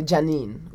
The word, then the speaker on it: Janine: Janine is, my name is actually Janine, which okay. is spelled J-A-N-I-N-E.